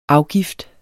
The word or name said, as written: afgift